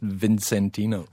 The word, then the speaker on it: Vicentino: It's spelt V-I-C, but apparently pronounced Vicentino.